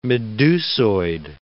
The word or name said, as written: medusoid